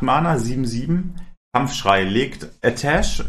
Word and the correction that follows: Atiesh: Nee, wie heißt die Waffe? Ich habe wohl das damals schon nicht aussprechen. Atiesh. Aber nicht mehr. – Wie ist Atiesh? Atiesh. Ja.